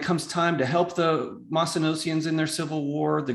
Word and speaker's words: Mycenaeans: Chapter four, there's these people called them, Jeff, you might have to help me with the pronunciation, Mycenaeans. – Yeah, Mycenaeans.